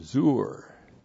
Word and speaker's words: zur: And then we have Zur. Z long U R, Zur.